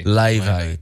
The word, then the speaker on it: Laufey: Leve. – But she's spelled L-A-U-F-E-Y.